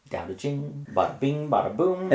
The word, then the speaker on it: Dao De Jing: That's pronounced with a D? – Yeah, that's not really the Taoist's fault, but when they started to write Chinese into English letters, there was a lot of mistakes made.